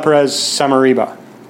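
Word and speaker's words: Pérez Somarriba: Estela Perez Samariba. – Correct. – If I said that correctly. – Yep, correct.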